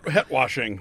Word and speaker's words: het-washing: Het-washing.